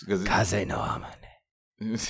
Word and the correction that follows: Kaze no Namae: Kaze no Namae.